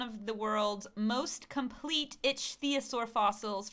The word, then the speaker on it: ichthyosaur: Okay, so in Patagonia, an intact pregnant ichthyosaur, it's spelled I-C-H... ichthyosaur, which is like ichthyosaur. – It's an itchy sore.